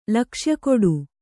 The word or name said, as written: ಲಕ್ಷ್ಯಗೊಡು